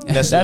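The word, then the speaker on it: Nestlé: That's how you pronounce it over there? Yeah, Nestle.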